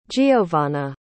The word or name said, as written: jeovana